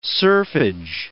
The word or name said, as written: serfage